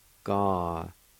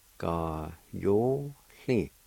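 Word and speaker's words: gayohli: Ga-yo-hle. Ga-yo-hle. Ga-yo-hle.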